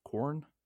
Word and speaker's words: Quarren: Corn? I mean, the way it's spelled is Quarren, but it sounds like they're saying corn. Corn? – Corn?